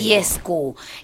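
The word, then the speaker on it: Dieskau: No sé si se dice así, Fischer Diesco. – Seguro que la primera está bien pronunciada, la segunda te la digo. – Diesco.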